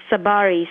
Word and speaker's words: Tsabary: The first name is Shefahli, that's spelled S for Sam, H for Harry, E, F for Frank, A-L-I. And the last name is Tsabary, spelled T for Tom, S for Sam, A, B for boy, A-R-Y.